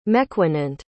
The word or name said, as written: mequanent